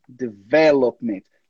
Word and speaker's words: development: Development. Lá atrás você falou development. A ênfase aqui tá antes. Tá no V.